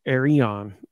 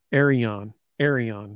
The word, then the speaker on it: Arion: It's Arion. Like, you got to emphasize the E in the middle. I got that from Tennessee's speak to text, whatever, pronunciation guide. Arion.